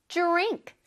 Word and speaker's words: drink: 근데 drink라고 발음하지 않아요. drink가 아니라 drink. 살짝 J 발음을 내줄까요? 처음에 drink, drink.